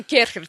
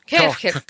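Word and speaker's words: Kärcher: А ты знаешь, ну Кёрхер читается правильно. Кёрхер.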